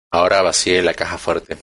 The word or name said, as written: caja